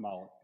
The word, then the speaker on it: Moloch: Yeah, it's Moloch.